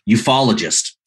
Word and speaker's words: ufologist: UFOologist, I'm saying that correctly. – No, I'm saying it incorrectly. – Ufologist.